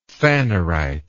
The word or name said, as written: phanerite